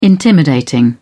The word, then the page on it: intimidating: British English